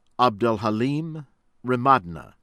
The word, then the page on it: Abdelhalim Remadna: American English